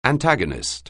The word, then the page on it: antagonist: anglais